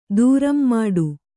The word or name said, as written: ದೂರಂಮಾಡು